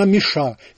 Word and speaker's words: Миша: Ну, а французы называют меня Миша. Именно так, с ударением на последней гласной, подчеркивает автор.